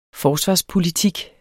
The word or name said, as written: forsvarspolitik